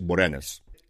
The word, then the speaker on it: Boreanaz: Boreanaz se pronuncia así en inglés, Boranas.